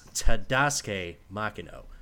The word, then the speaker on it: Tadasuke Makino: a rookie named Tadasuke Makino, whose name is never pronounced right on Formula 2 broadcast. – Makino? – God damn it, Alex Jakes. – It's Tadasuke Makino.